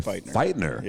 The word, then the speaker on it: Feightner: Okay, Feightner.